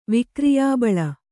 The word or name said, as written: ವಿಕ್ರಿಯಾಬಲ